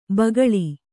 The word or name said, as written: ಭೂಗೋಳ